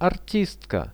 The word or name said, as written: artistka